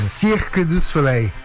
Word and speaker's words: Cirque du Soleil: Cirque du Soleil.